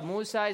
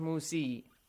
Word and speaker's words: Musa: So Musa is not pronounced Musi. – Musi. – Musa.